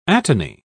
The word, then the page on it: atony: British English